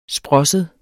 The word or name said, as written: sprosset